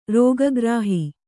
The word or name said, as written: ರೋಗಗ್ರಾಹಿ